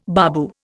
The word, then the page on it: babu: Indian